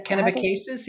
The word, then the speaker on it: Kennebecasis: Kennebecasis. – Yeah, Kennebecasis, yep.